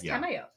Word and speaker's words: Tamayo: Tamioka. – I can't. No, it's Tamayo. – I can't remember. Tapioca. Anyways, the cool lady who's a doctor and actually takes care of people. – Yeah, Tamayo.